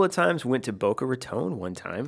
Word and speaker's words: Raton: Raton.